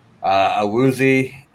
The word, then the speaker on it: Awuzie: Defense, with the ads they had, Awuzie, Ogunjobi. Am I bad? – Yeah, you got it.